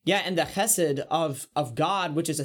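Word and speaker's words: Chesed (חסד): hesed or hesed, I can't remember how it's pronounced. – Chesed. – That's right. Yep, we get the chesed.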